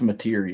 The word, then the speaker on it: Mateer: You got is it pronounced Mateer for Oklahoma? – I think it's Mateer, yeah.